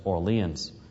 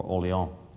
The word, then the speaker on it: Orléans: And so it was around 1528, Calvin is moved from Paris down to a town that we would pronounce the name as Orleans, or Orléans.